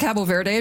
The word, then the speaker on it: Cabo Verde: You said Cabo Verde. I'm never sure how to say these things. Cabo, yeah.